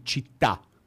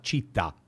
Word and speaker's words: città: Na Itália, você città. Hum. Então, se você fala città, não dá.